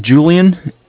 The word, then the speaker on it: Julian: My name is Julian. It's J-U-L-I-A-N. – Okay. – I'm calling with Bank of Cardiff. It's spelled C-A-R-D as in David, I-F-F, like Frank Frank.